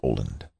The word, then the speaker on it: Aaland: Photoshop CS2 RAW, A-A-L-A-N-D, Mikkel Oland. Spelled Oland, pronounced Oland.